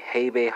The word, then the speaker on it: habe: Participle is spelled C-O-R-R-I-G-E-E-R-D. German folks, let's go from Dutch to German. It's going to be habe, hast, hat, haben, korrigiert. Helping verbs is spelled H-A-B-E-H-A-S-T-H-A-T-H-A-B-E-N. Participle is spelled K-O-R-R-I-G-I-E-R-T.